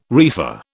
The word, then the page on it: reefer: British English